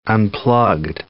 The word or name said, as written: unplugged